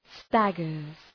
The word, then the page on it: staggers: ‘stægərz